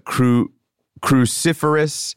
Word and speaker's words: cruciferous: I'm going to say it wrong, but cruciferous. – You got it.